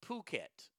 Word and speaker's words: Phuket: Oh, it's pronounced the Phuket. Okay, thank you.